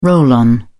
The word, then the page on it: roll-on: British English